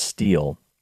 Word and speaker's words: stele: Now, steel is spelled S-T-E-L-E. Sounds like stele, but it's actually pronounced steel.